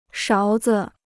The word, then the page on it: 勺子: sháo zi